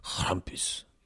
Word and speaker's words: Krampus: Krampus. That's how they say it because like it's German. Krampus.